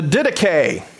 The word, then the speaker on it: Didache: So, the Didache.